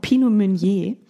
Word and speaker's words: Pinot Meunier: Ich war so im, im Drive von Pinot Meunier, was ich fantastisch Natürlich.